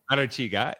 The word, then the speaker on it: Arechiga: Arechiga,